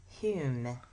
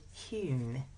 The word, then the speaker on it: Home: His name was Daniel Douglas Hume. Even though it was spelled Home, he was Scottish, and he pronounced it Hume. He pronounced it as if it was spelled H-U-M-E, but it was Home, H-O-M-E.